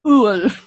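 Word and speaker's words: Öl: Oh, O double dot L. Ul. – That's it? Just 2 letters? – Well, it's more about the pronunciation.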